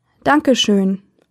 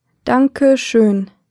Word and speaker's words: Danke schön: In Germany, thank you is Dankeschön. Danke schön. Let's break it down by syllable.